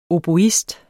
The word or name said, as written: oboist